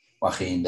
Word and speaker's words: Joaquin: I always forget how to pronounce that fella's name. Joaquin Buckley. – Joaquin, there you go. I'll always say that name wrong.